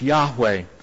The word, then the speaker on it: Yahweh: In Hebrew, we believe that name is pronounced Yahweh.